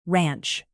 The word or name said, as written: ranch